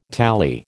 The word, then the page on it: tali: American English